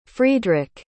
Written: friedrick

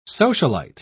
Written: socialite

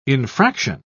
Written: infraction

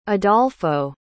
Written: adolfo

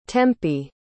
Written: tempie

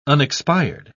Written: unexpired

